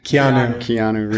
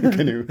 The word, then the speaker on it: Keanu: Keanu Reeves? – Yes. – That's not how you say his name, right? – Keanu Reeves. Canoe. – Canoe.